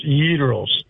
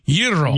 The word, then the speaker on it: gyros: The proper pronunciation for gyro, whatever, a Greek restaurant owner jumped on my butt one time 40 years ago. It's pronounced gyros. That's the pronunciation. – Gyros. You got to roll the R even. Yiro.